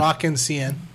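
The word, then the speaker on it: Rock en Seine: While the band was preparing to go on stage at the Rock Insane, if I'm saying that properly, festival in Paris. – Paris, yeah. – An argument broke out with... – Rock Insane. – There you go.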